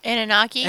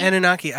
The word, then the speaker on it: Anunnaki: Anunnaki? – Anunnaki.